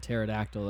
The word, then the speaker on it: pterodactyl: There's a P in front of pterodactyl, though. It doesn't really make a whole lot of sense.